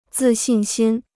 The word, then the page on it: 自信心: zì xìn xīn